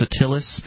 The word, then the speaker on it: Atilis: but it's spelled A-T-I-L-I-S, so it looks kind of like Atlas, but it's not Atlas, it's Attilis.